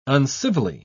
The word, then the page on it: uncivilly: British English